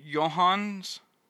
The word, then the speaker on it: Joonas: I think it's pronounced Johans. J-O-O-N-A-S, but I know he's Norwegian, so I think it's Johans.